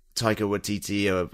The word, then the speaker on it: Taika Waititi: And, you know, Taika Waititi, I can never pronounce his name properly. – I think you've done it.